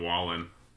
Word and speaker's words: Wallen: How do you say his name? Wallen or Waylon? – I think it was Morgan Wallen.